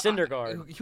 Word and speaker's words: Syndergaard: Syndergaard. – I don't even know how to pronounce his name. – It's Syndergaard.